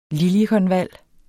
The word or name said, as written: liljekonval